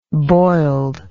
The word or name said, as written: boiled